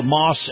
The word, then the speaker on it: Maus: It's called Moss Acura, and Moss is spelled M-A-U-S.